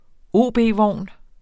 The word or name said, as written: OB-vogn